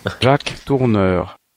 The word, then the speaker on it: Jacques Tourneur: Jacques Tourneur.